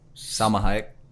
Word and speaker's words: Salma Hayek: Salma Hayek.